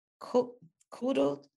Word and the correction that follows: coddled: How do you say that? Coddled, like cuddling a child.